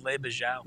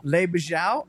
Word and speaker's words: Le Bijou: And congratulations to my father on Coop's list, the Little Bijo. Is that how you say it? – I think it's Le Bijou. – Le Bijou, 1922.